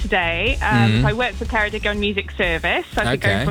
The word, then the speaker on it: Ceredigion: Well, Ceredigion in Wales. Hello. Happy birthday. – Hi. Thank you. What a lovely pronunciation of Ceredigion.